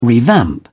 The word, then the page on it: revamp: British English